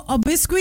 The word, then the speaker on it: obsequious: सो ओबिस्को इसकी स्पेलिंग है OBSEQUIOUS.